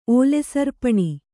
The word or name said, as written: ಓಲೆಸರ್ಪಣಿ